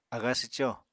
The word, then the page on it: aga swco: Apatani